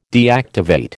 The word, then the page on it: deactivate: American English